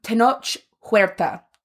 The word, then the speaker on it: Tenoch Huerta: okay, he has a way Tenoch Huerta has a way of yeah, that's the way you pronounce it.